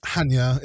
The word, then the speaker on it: Chania: Hania. It's quite expensive if you go high season. Hania, Crete. It's spelled Chania.